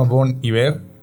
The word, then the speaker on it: Bon Iver: Pero una de esas, yo creo que no será mi responsabilidad, es que cada quien, pero eh, se llama Bon Iver. Bon Iver se pronuncia.